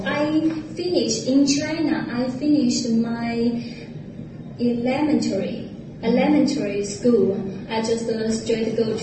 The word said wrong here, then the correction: elementary: She put the stress on the second syllable instead of the third, and she needs to change which syllable she's stressing.